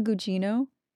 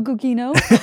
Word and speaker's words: Gugino: It's Carla Gugino. Not Carla Gugino. This whole time I've been calling her Carla Gugino. – That's funny. I wouldn't even have thought to pronounce that second G like that.